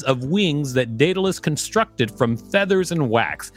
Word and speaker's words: Daedalus: Is it Daedalus or Daedalus? – Daedalus.